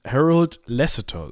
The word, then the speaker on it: Harold Lasseter: Und zwar möchten wir heute sprechen über einen Mann namens, namens Harold Lasseter. So würde ich ihn aussprechen. Ist das richtig? – Das ist richtig, auch wenn er so nicht hieß.